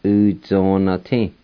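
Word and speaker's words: Uzonati: U-zo-na-ti. U-zo-na-ti. U-zo-na-ti. And sometimes they'll say U-zo-na-ti. The A with the N sound will be silent. Either way is correct.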